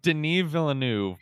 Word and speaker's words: Denis Villeneuve: How do you say Denis Villeneuve, but yes.